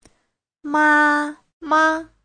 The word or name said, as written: ma1ma5